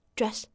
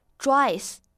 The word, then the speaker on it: dress: 哎，很多同学会把它错误的读成了双元音 dress，这个呢就错的非常明显了。真正的短元音哎，口型没有那么大。